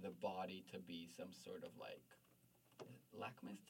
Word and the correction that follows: litmus: test. Is that how you say it in English? Like litmus test? – Litmus test.